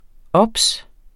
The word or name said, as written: obs